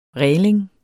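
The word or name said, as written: RÆLING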